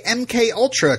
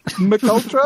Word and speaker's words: MK Ultra: he knew how to say MK Ultra correctly. M and K are pronounced as individual letters, not as Mick. – Correct. – What? Mick Ultra? – Mick Ultra.